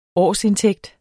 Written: årsindtægt

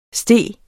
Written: steg

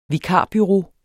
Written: vikarbureau

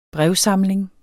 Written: brevsamling

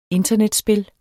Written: internetspil

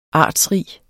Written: artsrig

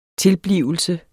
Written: TILBLIVELSEN

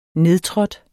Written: nedtrådt

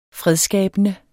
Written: fredsskabende

